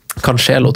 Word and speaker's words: Cancelo: Cancelo droppet vi. – Cancelo, tror du uttales. – Ja, riktig. Sorry. – Cancelo.